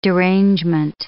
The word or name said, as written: derangement